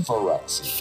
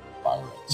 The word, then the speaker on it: Phyrexia: Phyrexia. – Phyrexia. – Phyrexia, sorry. – Yeah. – Phyrexia.